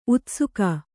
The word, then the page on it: ಉತ್ಸೇಕ: Kannada